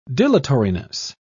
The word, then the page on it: dilatoriness: British English